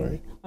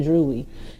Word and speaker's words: dreary: Sorry. Dreary. I said drewy.